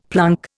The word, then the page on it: plunk: Indian